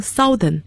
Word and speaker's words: southern: N-O-R-T-H-E-R-N 那么南方的英文是 S-O-U-T-H-E-R-N 要注意发音，很多人有可能读成 Southern, 但是正确的发音是 Southern OU 字母组合读成了啊这样的声音。